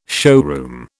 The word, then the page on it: showroom: British English